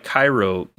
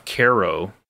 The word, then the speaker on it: Cairo: Side fact, apparently this town of Cairo, like they pronounce it like the syrup, even though it's spelled like the city in Egypt.